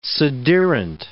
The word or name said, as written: sederunt